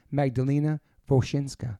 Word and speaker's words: Magdalena Wosinska: Please welcome to the podcast, Magdalena Wosinska.